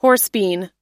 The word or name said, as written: horsebean